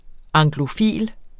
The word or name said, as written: anglofil